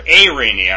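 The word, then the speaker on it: arene (εἰρήνη): Irene, or arene, I'm sorry. It should be arene. Arene. Arene. That's how you pronounce it. I'm not going to get these exact every time, but I know it's right, so bear with that. It's arene. Arene.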